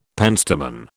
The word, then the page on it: penstemon: British English